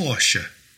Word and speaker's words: Porsche: It should be Porsche. Porsche.